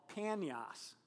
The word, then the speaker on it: Banyas: And just as an interesting side note, when the Arabs came in and took control of the region, they don't pronounce the P sound, and so they called it Banyas.